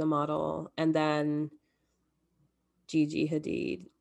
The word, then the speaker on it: Hadid: so now I forget the correct way to say their last name. – It's Hadid. – Gigi and, okay, cool. I think my sister says Hadid.